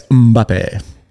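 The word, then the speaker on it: Mbappé: Do you pronounce that like Mbappe? – That's a good, is it like that song like Mbappe, Mbappe, Mbappe, Mbappe? Well, yeah, it's Mbappe.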